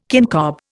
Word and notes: kincob: Indian

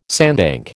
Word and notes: sandbank: American English

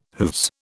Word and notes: hooves: British English

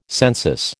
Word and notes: census: American English

